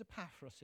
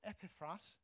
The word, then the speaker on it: Epaphras: The key person is Epaphras in this letter, or Epiphras, as you probably should pronounce it.